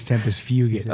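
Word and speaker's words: Tempus Fugit: I think it's "Tempest Fugit." – Oh, yeah, it's Latin. Not a hard G.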